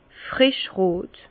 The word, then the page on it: frischrot: Bas Rhin; Herrlisheim